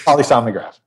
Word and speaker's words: polysomnograph: You said there's nothing is nowhere near the polysonogram. Did I say that correctly? – Close enough. – Okay. – Polysomnograph. Yeah. – Somnograph.